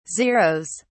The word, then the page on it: zeroes: British English